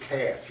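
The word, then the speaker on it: kaph: Today we're at verse 8, Psalm 119:81, and this word here is calf. You pronounce it with a K if that's at the top here, but here's what calf is.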